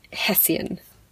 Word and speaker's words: Hessian: Yeah, it's a double S, but that's how I pronounce it, and yeah. – But SI often creates a sh sound, so, like, it could do that. – Yes, well, according to my Scottish friends, it's Hessian.